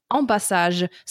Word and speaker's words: ambassage: But the site, the next site I went to, like literally legit pronounced it ambassage. So like literally French.